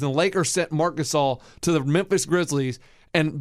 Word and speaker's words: Gasol: And forever, I called him Gasol. – Pau Gasol. And then I finally learned that the brothers were named Gasol. And so because of them, I now will forever be able to pronounce Gasol.